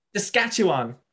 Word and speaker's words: Saskatchewan: As a full-fledged Canadian, how do you pronounce... It begins with S, sketch. It's like a little farm. I think it's a farm place. Saskatchewan. Is that how you pronounce it? – Saskatchewan? – Saskatchewan. That's how you say it, right?